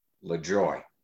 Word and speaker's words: Lajoie: And he said, my uncle and I always pronounced it Lajoie.